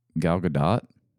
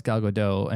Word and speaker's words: Gal Gadot: Gal Gadot? – It's pronounced Gal Gadot and no, but somewhat in the realm, yeah.